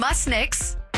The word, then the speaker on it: Munsick: I keep mispronouncing Ian Musnick's name. Mm-hmm. Moonsix. Mm-hmm. What am I saying? – You want to hear it from the man himself? – I do. Let me hear it. – Okay, and he's going to give you a little hint on how to pronounce it as well. – This is Ian Musnick, a.k.a. Munsick with it.